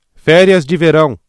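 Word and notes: férias de verão: Brazilian Portuguese

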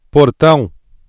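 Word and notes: portão: Brazilian Portuguese

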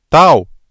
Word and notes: tal: ブラジルポルトガル語

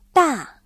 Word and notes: 大: dà

